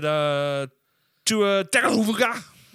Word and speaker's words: Tagovailoa: It's Tua. How do you say that? – Is it Tua Tagovailoa? – That's the one.